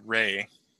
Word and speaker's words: Ralph: It's Ray. Even though I know it says Ralph, but it's generally pronounced Ray.